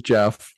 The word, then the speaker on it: Geoff: It is Jeff, just like J-E-F-F.